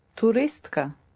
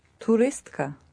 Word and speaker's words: туристка: Туристка. Туристка.